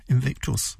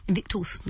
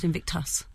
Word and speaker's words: Invictus: Well, Church Latin, Invictus. – Invictus, not Invictus. Lovely.